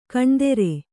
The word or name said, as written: ಖೂನದೋರು